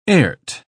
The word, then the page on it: airt: British English